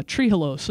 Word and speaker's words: trehalose: Am I saying that right, Fuzz? – Yep. – Yeah, trehalose.